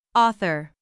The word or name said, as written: author